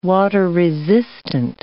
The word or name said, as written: water-resistant